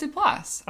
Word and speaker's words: Duplass: I thought it was Duplass. – I'm pretty sure it is.